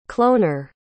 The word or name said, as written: cloner